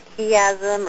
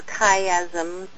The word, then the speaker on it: chiasm: Okay, well, I wanted to be sure that we all understood that chiasm or chiasm, people pronounce it both ways, or chiastic structure is a literary term.